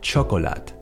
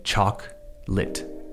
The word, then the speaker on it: chocolate: If you said chocolate or chocolate, then try again. This time we are turning, estamos convirtiendo, a three syllable word into a two syllable word. I want you to say chalk lit. Nada de choco, no. Chalk, chalk, como tiza, chalk lit. Lit as in the past tense of the verb to light, lit.